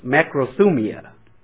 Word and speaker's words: makrothumia: Now the second Greek word is makrothumia. Makrothumia, which is spelt M-A-K-R-O-T-H-U-M-I-A. M-A-K-R-O-T-H-U-M-I-A.